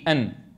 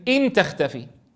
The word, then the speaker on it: أن: قال بفتح أن. إيه ليش يعني قال بفتح أن؟ حتى لا تلفظ بالكسر، إن تختفي. واضح؟ لماذا قال بفتح أن؟ حتى لا ينطق البعض بكسر الهمزة إن تختفي فيتغير المعنى.